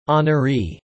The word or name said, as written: honoree